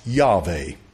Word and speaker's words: Yahweh: Now today there's a big dog fight about the fact that they say, well, Yod-Heh-Vav-Heh with the Masorete vowel points, they just took it from Adonai and they put those vowel points on it, and it should be pronounced Yahweh.